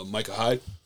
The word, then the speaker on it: Micah Hyde: Micah Hyde.